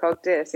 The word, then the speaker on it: CogDis: And this is Cogdis. I'm not sure how you pronounce it. – Yes. – Yeah. – Yes, that's perfect. Cogdis.